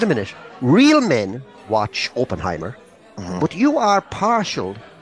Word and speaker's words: Oppenheimer: You don't pronounce the H, you don't, Jimmy? – Oppenheimer, you do.